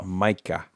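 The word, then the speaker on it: Maika Monroe: However, this week, they are adding to that cast Makia Monroe, local girl. – Still can't say her name right. – Makia Monroe. – Micah. – Micah. It's always going to be Macchia to me.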